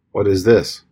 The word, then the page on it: what is this: English